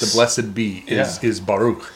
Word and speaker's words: Baruch: Yeah, Baruch. – Baruch.